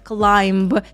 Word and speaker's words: climb: Здесь важно помнить о том, что последняя буква B в данном слове — это silent letter, то есть мы не произносим эту букву. Как часто я исправляю своих студентов, когда они говорят climb. Это неправильно, буквы B там быть не должно, climb.